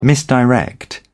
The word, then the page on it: misdirect: British English